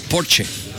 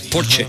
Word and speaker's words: Porsche: Y vino el director de Porsche al programa y me dijo, es Porsche, no Porsche. Porque en Alemania pronuncian todas las letras, Porsche.